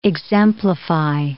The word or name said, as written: exemplify